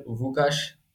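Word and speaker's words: Łukasz: I'm so sorry to any Polish listeners if I'm saying this terribly, but he's got the L with the line, right? And that's apparently pronounced like a W. It's very oversimplified. But so he'd be something like Marcin Bulka or something. But like I do know they call West Ham's goalkeeper Vukas Fabianski. So by that logic, I'm assuming that the same applies here.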